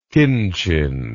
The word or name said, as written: kinchin